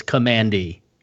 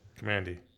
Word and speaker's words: Kamandi: While we were recording, I did tweet it out, and the overwhelming majority says that it's Kamandi. – Kamandi.